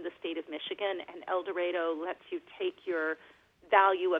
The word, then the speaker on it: El Dorado: Yeah, so actually they, strangely enough, pronounce it El Dorado.